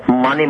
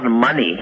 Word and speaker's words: Mani: For simple reason we say it's called Mani Mantra, it's not money, as Om Mani Padme Hum.